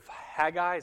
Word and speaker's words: Haggai: He's a contemporary of Haggai. Is that how you say his name? Right.